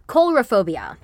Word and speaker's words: coulrophobia: Coulrophobia, Vincent said. That's what you have. How do you say it? It's like coulrophobia, Vincent said.